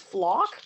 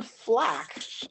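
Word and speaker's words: Flach: Oh, apparently his last name is pronounced Flock. Oh, that's even better, actually. – Who? – Leon Flack, but it's Flock.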